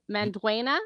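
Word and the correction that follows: Xolo Maridueña: No, Holo Mariduena. – Holo Mariduena.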